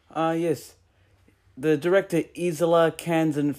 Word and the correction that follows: Elia Kazan: first toyed with the idea Elia Kazan, sorry. – Yep, Kazan, sorry. I tried pronouncing.